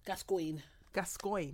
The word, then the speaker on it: Gascoigne: Gascoigne. – What does that mean? No, you said it right.